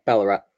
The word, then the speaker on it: Ballarat: I mean, we're not talking about, is Ballarat is the correct pronunciation, right? – Yeah, Ballarat.